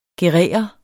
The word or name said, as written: gerere